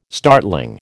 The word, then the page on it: startling: American English